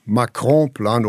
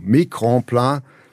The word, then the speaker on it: Macron-Plan: haben ein hohes Maß an Sympathie für diesen also Macron-Plan oder Entschuldigung, Macron-Plan kennengegeben und sich durchaus positiv dafür ausgesprochen.